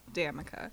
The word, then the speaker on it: Damica: By the way, my name is pronounced Damica. Literally, people get so afraid to say the damn part. – Say it part. – Again. – Damica.